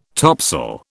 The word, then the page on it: topsail: British English